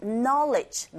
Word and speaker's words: knowledge: Knowledge. 끝발음에 강세 주지 마시고, knowledge. knowledge 아니에요. knowledge.